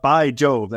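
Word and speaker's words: Bai Jobe: I'm just wondering, we're talking about By Jobe. That is literally his name. B-A-I is how you spell it. Space J-O-B-E.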